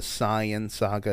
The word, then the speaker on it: Saiyan: When they got to the Saiyan Saga, or the Saiyan Saga is the correct way to say it, the Saiyans, quick lesson for anybody. Saiyan is an anagram of, well, Saiyan comes from Saiyajin, the Japanese word, which is an anagram of Yasai, which means vegetable.